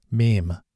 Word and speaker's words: meme: Because these self-replicating ideas were so much like genes, Dawkins coined the term meme, a mnemonic gene, pronounced to rhyme with gene.